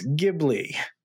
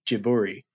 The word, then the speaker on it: Ghibli: Ghibli, or Jiburi, as I found it's more accurately pronounced.